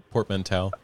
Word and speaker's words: portmanteau: Portmanteau.